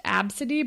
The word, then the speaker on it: ABCDE: her name was ABCDE, and it's pronounced Absidy, but it looks like the first six letters or five letters of the alphabet or whatever.